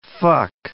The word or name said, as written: fuck